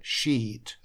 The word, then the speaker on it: sheet: А это sheet, обязательно звук и тянем.